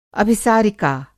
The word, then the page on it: अभिसारिका: Hindi